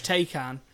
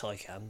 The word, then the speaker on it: Taycan: I think when you see like YouTube clips of like the Porsche Taycan and it is a Taycan, isn't it? The electric one. – I think it's Taycan, but yeah.